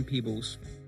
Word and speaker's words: Peebles: Perhaps I wasn't made aware of Ann Pebbles. I hope I'm saying her name correctly. Pebbles, correct? Yeah. Not Peebles. – But it's double E, so maybe Peebles. It is Ann Peebles.